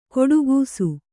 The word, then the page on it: ಕೊಡುಗೂಸು: koḍugūsu